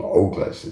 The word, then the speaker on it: Ogles: In a now depleted ex post published last week, rapidly anti-gay Representative Andy Oglas I think he pronounces his name Ogles. It's O-G-L-E-S.